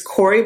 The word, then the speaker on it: Kouri: It's Corey, but it's spelled K-O-U-R-I. – K-O-U-R-I.